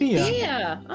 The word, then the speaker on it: Fiadh: Fiadh. – Fiadh.